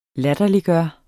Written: LATTERLIGGJORDE

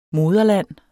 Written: moderland

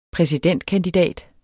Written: præsidentkandidat

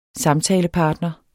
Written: samtalepartner

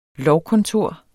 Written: lovkontor

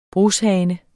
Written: brushane

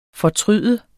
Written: fortryde

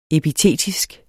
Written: epitetisk

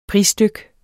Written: prisdyk

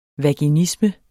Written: vaginisme

